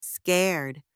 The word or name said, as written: scared